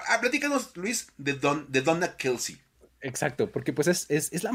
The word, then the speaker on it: Kelce: Entonces, todo el mundo le dice Travis Kelsey. Entonces. – En el oficial se le ocurrió decir, no, es que en realidad se pronuncia Kels.